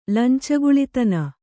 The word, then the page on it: ಲಂಚಗುಳಿತನ: kannada